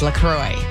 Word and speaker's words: LaCroix: And if you've ever seen it, you're like, how do you say that? Is it French? No, it's Wisconsin. Actually, it's a cross between La Crosse, Wisconsin, and St. Croix River in western Wisconsin. So it's LaCroix. It's not French.